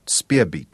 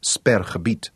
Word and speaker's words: Sperrgebiet: She pronounced it Speerbeet instead of Sperrgebiet in her enchanting accent.